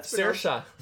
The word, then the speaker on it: Saoirse: It's Swarzy? Not pronounced Swarzy? IKEA. – Saoirse. – Saoirse.